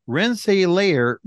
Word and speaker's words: Rensselaer: Oh boy, I can't say that right. Rensselaer, New York. There we go.